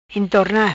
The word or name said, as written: Entorna